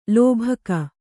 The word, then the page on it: ಲೋಭಕ: lōbhaka; kannada